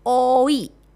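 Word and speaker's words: oi (오이): Nah, 2 Mei sendiri dipilih karena bahasa Korea pelafalan itu sama seperti kata timun itu sendiri, yaitu oi. Ya, 2 itu oi, Mei itu o.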